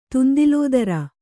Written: ತುಂದಿಲೋದರ

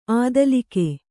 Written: ಅಧೋಲೋಕ

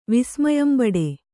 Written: ವಿಸ್ಮಯಂಬಡು

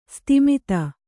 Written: ಸಿತಮತಿ

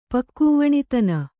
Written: ಪಕ್ಕೂವಣಿತನ

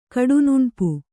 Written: ಕಡುನುಣ್ಪು

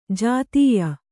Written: ಜೊತ್ತಾಯ

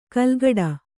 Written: ಕೆಲಕಡೆ

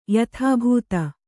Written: ಯಥಾಭೂತ

